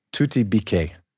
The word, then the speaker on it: Tuti Bike: Because her last name is spelled B-I-K-E. – Yeah, but it's pronounced in Russian, Tuti Bike.